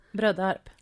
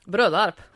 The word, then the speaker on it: Brödarp: Ja, men jag är ju här nu och hälsar på dig ute i, hur var det uttalades på västgötska? – Brödarp. – Brödarp. – Jajamän.